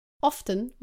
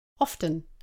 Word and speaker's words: often: Some people pronounce often with a T, often, and others don't pronounce the T, often.